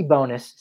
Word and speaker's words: Bones: Our final submission, Ricky Bonus, spelt bones, but pronounced bonus.